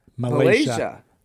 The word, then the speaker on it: Malaysia: Malaysia. Three syllables.